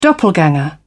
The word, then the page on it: doppelganger: British English